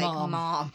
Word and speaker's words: ma'am: But then the way we pronounce ma'am, yes, ma'am, no, ma'am. – M-A-apostrophe-A-M. – You guys pronounce it like mom.